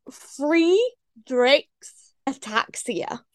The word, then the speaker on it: Friedreich's ataxia: It's called Friedreich's ataxia.